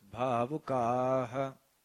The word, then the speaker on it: Bhavukaha: So you miss the emphasis on the last. Bhavukaha. That emphasis is missed out because the devotees start immediately chanting.